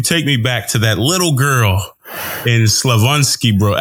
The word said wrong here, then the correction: Slavonski Brod: Before we keep going, teach me how to say it the right way. I want to come correct. – It's Slavonski Brod. – Slavonski Brod.